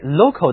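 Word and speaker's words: locale: 如果我们给 local 的后面加上字母 e，就变成了名词，读音中的重音也因为后面增加字母的缘故而从第一个音节后移到了第二个音节，读作 local。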